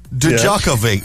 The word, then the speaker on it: Djokovic: like phonetically, Jock, J-O-C-K, space, O-H, space, V-I-T-C-H. So, this morning, Rory, Novak Djokovic he's into the French Open semi-final. – Say that again? – Novak Djokovic. – Perfect.